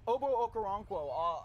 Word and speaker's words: Obo Okoronkwo: Obo Okoronkwo, outside linebacker out of Oklahoma.